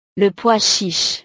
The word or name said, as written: le pois chiche